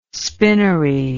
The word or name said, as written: spinnery